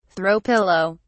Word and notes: throw pillow: British English

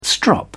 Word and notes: strop: British English

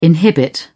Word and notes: inhibit: British English

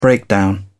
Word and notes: breakdown: British English

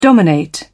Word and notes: dominate: British English

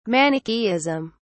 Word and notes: Manichaeism: British English

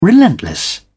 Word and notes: relentless: British English

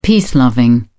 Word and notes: peace loving: British English

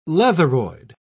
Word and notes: leatheroid: British English

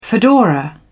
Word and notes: fedora: British English

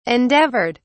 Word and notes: endeavoured: British English

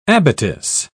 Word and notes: abattis: British English

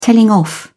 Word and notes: telling off: British English

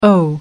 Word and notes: oh: American English

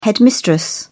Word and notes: headmistress: British English

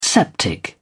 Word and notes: septic: British English

